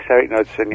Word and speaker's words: Knudsen: Yes, Eric Knudsen, yeah.